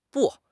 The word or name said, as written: bu4